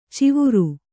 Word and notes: ಚಿವುರು: civuru

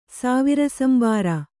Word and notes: ಸಾವಿರಸಂಬಾರ: kannada

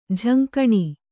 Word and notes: ಝಂಕಣಿ: jhaŋkaṇi

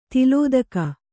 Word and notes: ತಲೆದೂಗು: taledūgu

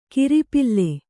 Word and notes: ಕಿರಿಪಿಲ್ಲೆ: Kannada

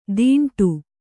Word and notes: ತೀಂಟು: Kannada

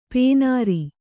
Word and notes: ಪೇನಾರಿ: pēnāri

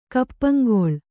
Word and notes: ಕಪ್ಪಂಗೊಳ್: Kannada

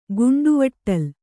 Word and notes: ಗುಂಡವಟ್ಟಲ್: kannada

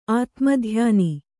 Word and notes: ಆತ್ಮಧ್ಯಾನಿ: ātmadhyāni

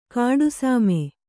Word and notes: ಗಿಡಸೀಮೆ: giḍa sīme

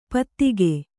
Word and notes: ಪೈತ್ತಿಕ: Kannada

